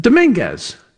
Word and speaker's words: Dominguez: D-O-M-I-N, presuming this is correctly spelled. D-O-M-I-N-G-U-E-Z. How do you pronounce that? – Dominguez. Hilario Dominguez, yes. – I said Dominguez. I put an extra I-N-G in there.